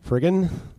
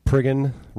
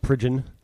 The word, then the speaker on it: Priggin: My name is Spike Priggin. – Priggin, yes. – Oh. Yes, it's Priggin. Rhymes with friggin, not Pridgin.